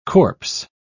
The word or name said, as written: corpse